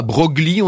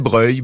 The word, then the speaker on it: Broglie: On disait pas Broglie, on disait Breuil.